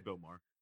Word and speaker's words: Maher: Bill Maher.